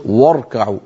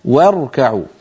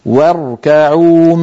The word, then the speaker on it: واركعوا: واركعوا عندما يتجاور حرف مرقق مع حرف مفخم، غالبا المفخم يطغى على المرقق إن تركنا الفم على هواه، فبدلا من أن نقول واركعوا الواو مرققة والراء مفخمة فإن الفم أسهل عليه فينطقهما كلاهما مفخمين، هكذا يقول واركعوا ور، والعرب لا تفخم الواو، لابد أن نقول واركعوا واركعوا مع الراكعين.